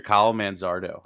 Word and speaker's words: Kyle Manzardo: But of course, they're going to call up their top top prospect soon and that's Mr. Kyle Manzardo.